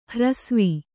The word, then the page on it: ಹ್ರಸ್ವ: hrasva